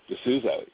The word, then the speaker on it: D'Souza: Yeah, D'Souza.